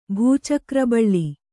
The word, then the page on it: ಭೂಚಕ್ರಬಳ್ಳಿ: kannada